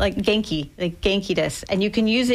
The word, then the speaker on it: genki: How do you pronounce that? – Genki. – Genki? – Genki. – Like with a G. – Hard G.